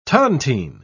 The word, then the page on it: tontine: British English